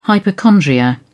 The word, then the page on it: hypochondria: British English